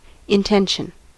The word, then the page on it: intention: American English